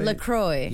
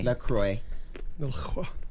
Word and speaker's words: LaCroix: LaCroix. – LaCroix.